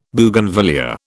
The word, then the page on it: Bougainvillaea: British English